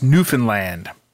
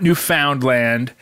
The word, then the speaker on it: Newfoundland: First of all, it's pronounced Newfoundland, like understand, not Newfoundland or Newfoundland, though you might be able to get away with that last one.